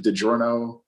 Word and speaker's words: DiGiorno: Literally just today I saw a commercial for DiGiorno. Is that how you pronounce it? DiGiorno pizzas? – Yeah.